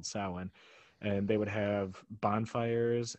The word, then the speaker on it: Samhain: It's spelled like Samhein, but it's pronounced Samhain for some reason.